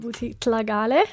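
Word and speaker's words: Buti Tlhagale: Welcome, Archbishop Buti Tlagale.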